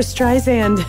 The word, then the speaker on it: Streisand: Barbara Streisand. Streisand, like sand on the beach, soft S.